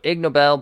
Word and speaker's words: Ig Nobel: So Ig Nobel Prize. – Ig Nobel? – Yeah. Like ignoble is, it's like a pun based off of ignoble. – Right. – So they call it the Ig Nobel Prize.